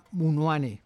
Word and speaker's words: Munuane: y que se pronuncia, como me enseñaba el propio Cucubi, se pronuncia Munuane, el cuidador de peces y otros relatos.